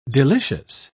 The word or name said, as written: delicious